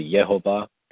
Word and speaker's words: Jehovah: I've heard that the vowels in the word Jehovah, or you know how the Jews pronounce it, Yehovah, I've heard that those come from the Hebrew Adonai, which does mean my Lord.